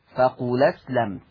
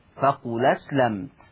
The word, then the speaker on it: قل أسلمت: فقل أسلمت فيه النقل. طيب هشيل الهمزة، طب والفتحة اللي عليها هحطها على اللام فتبقى لام مفتوحة فتنطق فقول أسلمت. فقول أسلمت تاني، فقول أسلمت.